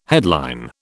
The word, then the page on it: headline: British English